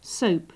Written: soap

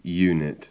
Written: unit

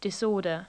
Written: disorder

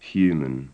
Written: human

